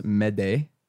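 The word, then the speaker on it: m'aidez: It's pronounced, actually in French, it's pronounced mede, and it means help me.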